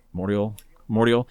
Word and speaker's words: Mordial: There's great songs on the other ones, but to me, Meta and the new one, how do you say it? – Mordial.